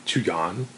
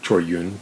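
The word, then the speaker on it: Chor Yuen: And I saw a film by Chu Yun, or Choi Yun, to use his Cantonese pronunciation, who is one of the stalwart Hong Kong or Shaw Brothers directors.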